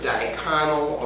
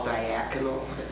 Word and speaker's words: diaconal: And it, of course, is the word from which all of these others are derived, diaconal, or sometimes it's called diaconal. It's, you'll hear it pronounced both ways, just as an adjective referring to service-oriented.